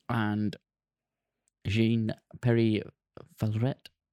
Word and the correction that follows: Jean Pierre: Jean Pierre.